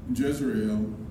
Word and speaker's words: Jezreel: But the Hebrew scholars will know that Jezreel rhymes with Israel. Jezreel is a place of God's judgment.